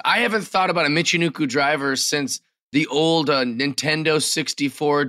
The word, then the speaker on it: Michinoku: I don't feel that they booked her well so far, but she did I'm going to say it wrong, a Michinoku driver. Did I say it right? – You said it right, but she's calling it a Piper driver.